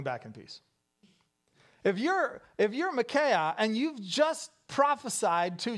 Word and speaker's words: Micaiah: Well, they have all these lying prophets around them, and they bring in one guy whose name we're going to pronounce as Micaiah.